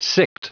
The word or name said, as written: sicked